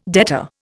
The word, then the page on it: debtor: Indian